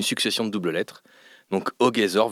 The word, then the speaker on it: Ogezor: Mais à présent, il faut vous appeler Ogezor, enfin O-G.E-Z.O-R. Alors pourquoi ce nom et comment ça se prononce ? – Alors, on va commencer par la prononciation, c'est Ogezor.